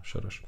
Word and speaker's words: Soros: A Sorožom. – A Sorožom. To je Šoroš po maďarsku.